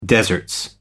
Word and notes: deserts: American English

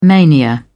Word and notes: mania: British English

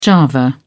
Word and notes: java: British English

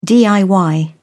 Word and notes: diy: British English

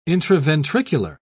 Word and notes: intraventricular: British English

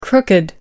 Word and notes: crooked: American English

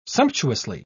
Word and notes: sumptuously: British English

